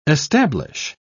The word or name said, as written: establish